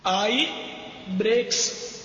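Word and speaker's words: Eyebrex: آئی بریکس اس کی spelling بنے گی غالباً ای Y E B R E x, آئی بریکس.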